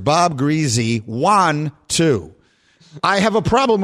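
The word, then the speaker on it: Griese: His last name is pronounced Greasy.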